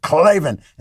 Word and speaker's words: Klavan: How did you hear about us, box, so they know we sent you, and say Klavan that way. You got to stick to it. Klavan. And then you got to spell it K-L-A-V-A-N.